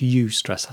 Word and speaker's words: eustress: So eustress, that's eustress, that's how it's pronounced, is E-U-S-T-R-E-S-S.